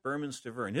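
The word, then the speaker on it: Bermane Stiverne: Berman Stiverne, and that's the way he wants his name pronounced, Berman Stiverne.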